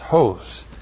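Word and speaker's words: hos (ὡς): H long O S, hos.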